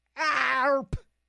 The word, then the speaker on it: ARP: A-R-P. ARP. That's how it's pronounced.